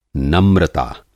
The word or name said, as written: नम्रता